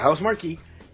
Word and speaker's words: Housemarque: Estuvo comprando primeramente el estudio detrás del desarrollo de Returnal, llamado Housemarque, no me queda claro bien cómo House se Marquee, pronuncia. – Yo creo que yo lo escuché mencionar Marquee de esa manera. – Sí, gracias, Housemarque, esto que es un estudio basado en Helsinki, y está detrás obviamente del título de Returnal, lo más reciente que han hecho.